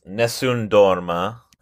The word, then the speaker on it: Nessun Dorma: So the name is Nessun Dorma.